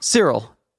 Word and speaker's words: Cyril: My name is Cyril Stevens. – Oh, it actually did sound like Cyril that time. Okay, cool. I was just making sure. – How do you say Cyril? – Cyril. – Cyril. – How do you spell it, Cyril? – Cyril, you spell it C-Y-R-I-L.